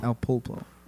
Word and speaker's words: El Pulpo: There's an L in there if I didn't say it correctly. – Oh, okay. – El Pupo. – Pulpo. – El Pulpo.